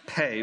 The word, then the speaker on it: pe: It is spelled P E, but it is pronounced pay.